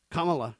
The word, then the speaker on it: Kamala: Kamala is how you say it, by the way. Kamala Harris. They say it's Kamala.